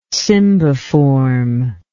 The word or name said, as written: cymbiform